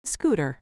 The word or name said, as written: scooter